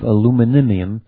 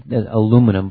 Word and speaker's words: aluminium: The term silver screen comes from the actual silver, or similarly reflective aluminium, however you pronounce that. It's really aluminum, but I guess in Europe they call aluminium.